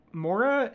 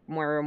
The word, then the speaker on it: Moira: It's spelled Moira. Like, we would say Moira or more, but it could just be an accent thing that it sounds different when different people say it.